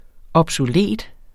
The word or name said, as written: obsolet